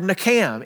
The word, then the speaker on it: nacham: nakam. N-A-C-H-A-M phonetically is how you would write it out.